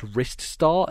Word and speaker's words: Rystar: there was something that said it's pronounced Rystar, as in like your wrist.